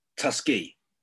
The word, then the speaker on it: Tuskegee: And I'd like you to explain to me and to the people listening about Tuskegee. That's the right way to say it.